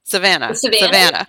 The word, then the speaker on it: Savannah: Am I saying that right? Savannah. – Savannah.